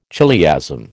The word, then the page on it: chiliasm: American English